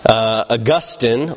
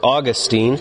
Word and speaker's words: Augustine: Tonight we are looking at the story of a great man of God, Augustine, or Augustine. I usually call him Augustine, but you'll hear it pronounced either way. You might hear me pronounce it both ways tonight.